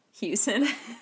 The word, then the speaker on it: Hughson: Yeah, so I'm at Shelb Hughson. My last name is H-U-G-H-S-O-N.